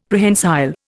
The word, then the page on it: Prehensile: Indian